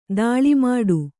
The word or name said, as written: ತಾಳೆ ಮಾಡು